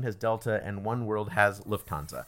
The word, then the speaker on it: Lufthansa: Is that Lufthansa? What is it? Did I say it right? – Lufthansa? Yes, that's an airline.